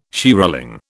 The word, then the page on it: shearling: British English